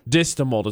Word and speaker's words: distamult: Distamult is how you pronounce it.